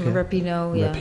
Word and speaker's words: Rapinoe: Eh, het Amerikaanse voetbalteam, de speelster Megan Rapinoe, zeg ik dat goed? Zeg Rapinoe, ja.